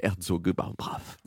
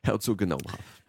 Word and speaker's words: Herzogenaurach: tout va bien maintenant à Herzogenaurach. J'ai complètement foiré ma dernière prononciation de cette ville, mais Personne ne peut vérifier de toute façon. – Exactement. – Personne. – Herzogenaurach.